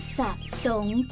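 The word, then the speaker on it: สรง: อ่านว่าสะสงจ้าแปลว่าสะสำหรับอาบน้ำ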